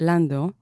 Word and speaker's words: Landau: Idan Landau, or in Hebrew it's pronounced Lando,